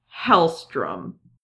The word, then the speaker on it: Hellstrom: Also, another annoying thing about Damon Hellstrom is that his name looks like it should be Hellstorm, and at some point he has gone by the code name Hellstorm. But his name is still Hellstrom, so I'm probably going to say it wrong at some point during this, because why would you do both of those? – I don't know.